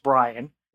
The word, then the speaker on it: Brien: But the show is called Ask Brian. It's spelled B-R-I-E-N. Nobody understands why it's Brian, but even worse than that, people don't understand why there's an E in Brian.